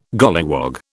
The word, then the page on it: golliwog: British English